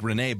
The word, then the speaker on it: Brené: How do you say that first name again? – That's my girl. I love her. – Brené? – Yeah, B-R-E-N-E. – Like Brené, but with a B.